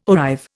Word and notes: arrive: Indian

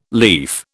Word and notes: lief: British English

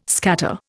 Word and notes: scatter: Indian

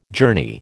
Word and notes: journey: American English

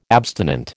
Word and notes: abstinent: American English

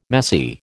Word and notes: messy: American English

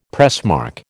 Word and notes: pressmark: American English

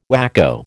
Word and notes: whacko: American English

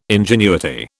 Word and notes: ingenuity: British English

